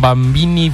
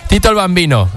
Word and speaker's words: Tito el Bambino: Un nom que no tothom sap pronunciar, alguns diuen Bambini, Bandini... Tito el Bambino, amb aquesta col·laboració, aquest featuring, juntament amb Saiko pel seu disc, aquest resultat, que és Dios los bendiga.